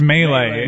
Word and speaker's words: melee: Lamar Thomas broadcasting in the broadcast booth while watching a melee that spills. Is it melee? Is that how we pronounce it? – No, it's melee.